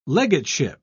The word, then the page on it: legateship: British English